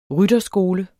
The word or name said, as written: rytterskole